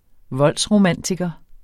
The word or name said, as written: voldsromantiker